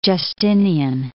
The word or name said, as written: Justinian I